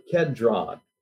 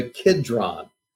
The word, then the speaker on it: Kidron: or over the Kedron, the Kidron. K-I-D-R-O-N is another way of pronouncing it.